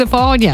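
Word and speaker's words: Le Fanu: It's spelled Lefanu. – Yeah. – But apparently it's Lefania. – Lefania. – I think I said that right.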